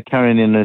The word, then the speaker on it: Karenina: Somebody said to me it was Anna Karenina. How do you say it? – Anna Karenina. Yeah, Karenina is probably more correct, but anything that comes out of my mouth without one is usually good enough.